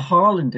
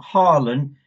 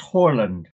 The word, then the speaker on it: Haaland: But on the other hand, you know, if you put a Haaland in there, I've been told by Norwegian friends it's not Haaland, it's Haaland. I'm going to pronounce it properly, Haaland.